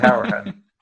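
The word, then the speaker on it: Hourihane: Is it Harahan? – Harahan. – Harahan, yeah. But that's actually often got wrong in Ireland. That's his Cork. That's a Cork pronunciation, but he's from Cork.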